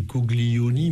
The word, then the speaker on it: coglioni: Si on lit les lettres, c'est « coglioni », mais en prononçant en italien, on prononce Coglioni et on commence à comprendre de quoi il s'agit.